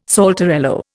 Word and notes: saltarello: Indian

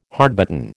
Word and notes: hardbitten: American English

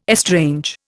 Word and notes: estrange: Indian

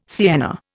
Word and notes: sienna: Indian

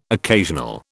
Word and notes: occasional: British English

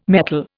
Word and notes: metal: Indian

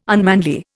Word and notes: unmanly: Indian

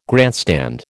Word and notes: grandstand: American English

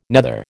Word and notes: nether: American English

